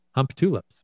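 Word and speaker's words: Humptulips: And I'm pretty sure you don't pronounce it that way, but it literally spells Hump Tulips. – That's pretty much how I've always heard it pronounced. – That's the only way I've heard it pronounced as well.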